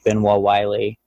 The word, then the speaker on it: Benoit-Wylie: And it's, again, Jake Benoit-Wylie. And you can just contact me on there through Messenger. – Let us do a little spelling of that, Jake, because you're pronouncing it in the French way. Benoit is spelled B-E-N-O-I-T, and then Wylie would be W-Y-L-I-E.